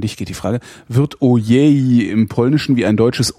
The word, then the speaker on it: Ojej: Also Ojej, also ein offenes ein offenes O sozusagen.